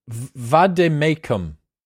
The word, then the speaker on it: vade mecum: What is a vade mecum? – A vade mecum is a Latin.